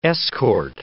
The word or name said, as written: escort